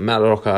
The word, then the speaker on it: Mallorca: Um, next game, we've got Malak Rocker, Malo Rocker. Sorry, I think I've pronounced that wrong. Malorach versus Real Sociedad. Um, we've got Mallorca at three dollars, the draw at three dollars twenty, and Real Sociedad at two dollars forty.